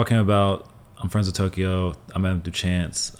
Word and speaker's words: Tokyo: Oh, yeah, Tokyo. – Tokyo.